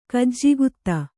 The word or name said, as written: ಗೆಜ್ಜೆಗುದಿ